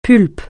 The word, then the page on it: pulpe: francais